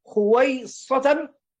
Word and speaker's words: خويصة: فقالت أم سليم: يا رسول الله، إن لي خويصة، هكذا تنطق لأنها تصغير لكلمة خاصة، فالمشدد فيها الصوت، يعني أمر من الأمور تختص هي بمحبته، وتريد النبي صلى الله عليه وآله وسلم أن يخصه بالدعاء.